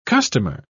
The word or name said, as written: customer